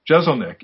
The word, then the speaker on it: Jeselnik: World-class comic Anthony, and I hope I pronounce his last name right, Jeselnik. Did I screw that up? Or? – No, you nailed it, man.